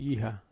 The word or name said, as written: iha